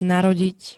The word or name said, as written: narodiť